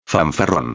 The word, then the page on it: fanfarron: espanhol